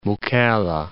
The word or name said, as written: Mukalla